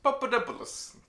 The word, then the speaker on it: Papadopoulos: Randomly, I feel like you pronounced the wrong name wrong. This is obviously Mr. Papadopoulos. – Papadopoulos.